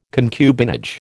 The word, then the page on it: concubinage: American English